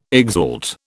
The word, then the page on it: exalt: British English